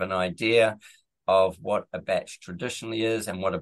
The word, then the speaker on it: bach: And for those of you who don't know what a batch is, it's spelled B-A-C-H and there's no T in it.